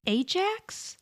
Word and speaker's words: Ajax: So he says, and it's pronounced Ajax, or N-A-J-X is how you pronounce it.